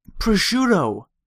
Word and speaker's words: prosciutto: Have you ever eaten bacon and or prosciutto? This is from Cousin Sal. – Prosciutto. That's how you say it.